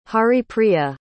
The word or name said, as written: haripriya